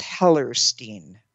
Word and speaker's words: Hellerstein: My name is Hellerstein. H-E-L-L-E-R-S-T-E-I-N. Go ahead and write it out. Now, when I first did this, I could not spell it backwards. I couldn't see the whole long Hellerstein.